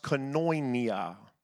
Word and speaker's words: koinonia: That word is a Greek word pronounced koinonia.